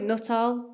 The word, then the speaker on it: Nuttall: I'd go with Nuttall, but I mean, however it comes out is fine.